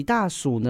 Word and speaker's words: 大暑: 大暑，不是大家尿气哦，千万不要这样子念哦。它是暑假的暑，好，所以有一点台湾国语哦。暑假的暑，好啊。如果呢，哎，那讲你休热哈，是休热啊，但是休暑假，我听过不？哎，休暑假，所以大暑呢，